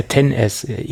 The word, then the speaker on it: XS: es ist ja 10S. XS wird es ja so nicht ausgesprochen. Es steht halt da nur so, ne? – Ja, So.